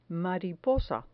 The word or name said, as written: mariposa